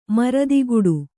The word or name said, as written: ಮರದಿಗುಡು